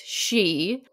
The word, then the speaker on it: sidh: Those things might be the malicious, it's pronounced she. I have no idea how it's pronounced she. It's spelled S-I-D-H.